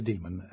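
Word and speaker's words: dæmon: Yeah, I pronounce it demon, but it's spelled D-A-E with a ligature, M-O-N.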